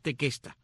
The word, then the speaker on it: Tequesta: Otros indios de esta área son los llamados tequesta o tecuesta, de acuerdo como lo menciones, lo pronuncias al inglés o el español, pero es tequesta. Tequesta con Q, T-E-Q-U-E-S-T-A, los tequesta, y los otros son los aega.